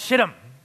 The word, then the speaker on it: Shittim: Scene takes place in a town called Shittim. That is how you pronounce it.